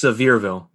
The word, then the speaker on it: Sevierville: It's Sevierville. That's how you say it.